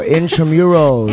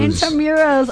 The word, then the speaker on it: Intramuros: How do you say it? Infirmary. – Oh, Intramuros. – Intramuros.